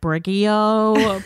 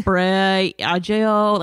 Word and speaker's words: Briogeo: It took a while to understand that it's pronounced Briogeo. I was saying like Briogeo, Briogeo.